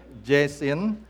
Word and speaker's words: Jasin: My name is Jacob Jasin, and my last name is spelled J-A-S-I-N, not J-A-S-O-N. Because of my last name, many people sometimes call me Jason, J-A-S-O-N. Jasin is a form of Jason, so I don't mind if people call me Jason.